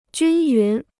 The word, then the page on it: 均匀: jūn yún